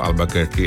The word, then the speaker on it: Albuquerque: è un nome che si scrive in tutt'altro modo ma si pronuncia appunto Albuquerque, e l'abbiamo imparato grazie ai Prefer Sprout.